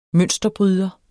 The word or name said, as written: mønsterbryder